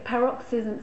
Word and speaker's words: paroxysms: Yeah, paroxysms.